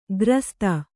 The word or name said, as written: ಗ್ರಸ್ತ